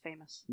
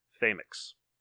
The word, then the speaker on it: Famoux: It's still pronounced famous. – No, it's not, because I went to her, by Cassandra Tate, I went to her website, and she has a little YouTube, a little video of where she's describing it, and she pronounces it Famix.